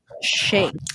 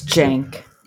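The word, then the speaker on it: Cenk: I had no idea it was pronounced Shank. – It's Jank. – This is news to me. – No one knows. – It's a J.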